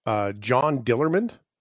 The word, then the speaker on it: John Dillermand: It's called John Dillermand.